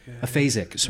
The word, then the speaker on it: aphasic: Aphasic.